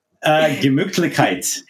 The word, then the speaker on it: Gemütlichkeit: what was that German word I couldn't pronounce? – Gemütlichkeit. Yes. – There it is. – Yes, that is the warm feeling. Is. Yep, exactly right.